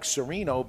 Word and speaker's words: Serino: But I like Serino because S-E-R-I-N-O, I can read it, I can see it, there's no problem. – It's pretty straightforward. – There's been zero mispronunciations of it. Everybody gets how it's pronounced right out of the gate.